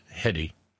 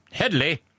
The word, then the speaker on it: Hedy: Hedy, pronounced Hedy, not Hedley,